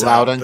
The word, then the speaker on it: Loudoun: Right now, it's Prince William or about to butcher the L name there, but Loudoun County.